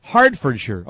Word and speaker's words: Hertfordshire: It's pronounced Hertfordshire. Oh, pardon me. Okay, Hertfordshire.